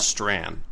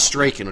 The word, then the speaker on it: Strachan: Owen Strawn, but not Strachan, which is how I always pronounce it.